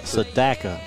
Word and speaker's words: Sedaka: Sedaka. – So like Dada, Daka. – Yes. – Sedaka.